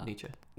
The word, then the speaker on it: Nietzsche: So, like, when I see a thing like that, like N-I-C-H-E, right? That's how you spell it. Nietzsche. – Well, N-I-E-T-Z-S-C-H-E.